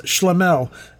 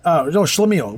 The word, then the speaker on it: Schlemiel: Okay, the definition of Schlemiel, pronounced Schlemel, Schlemiel, we are pronouncing it correctly, is a stupid, awkward, or unlucky person.